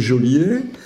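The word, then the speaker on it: geôlier: alors la même chose, en entendant les chansons euh, géolier ou, ou, Alors, si on regarde de près, il n'y a pas d'accent aigu. Donc, de toute façon, c'est Jolier. Et c'est aussi toujours ce problème du E qui est là euh, pour faire prononcer non pas gueux, mais je.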